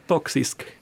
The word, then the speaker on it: toxisk: Att där finns det till exempel på svenska så finns det ju toxics, tos, toxics, nej, uttalas det toxics maskulinitet? Mm, to, toxisk.